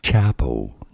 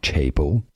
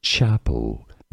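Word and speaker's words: Chappell: Chapel, not Chapel. Chapel, Roan.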